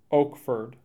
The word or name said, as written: Oakford